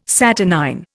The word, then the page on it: saturnine: Indian